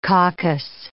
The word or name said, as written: caucus